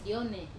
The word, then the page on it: one: wamesa